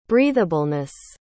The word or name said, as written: breatheableness